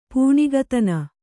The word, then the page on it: ಪೂಣಿಗತನ: pūṇigatana